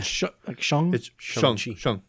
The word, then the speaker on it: Shang-Chi: Shang-Chi. Okay. – It's like Sean, but with a G.